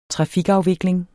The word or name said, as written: trafikafvikling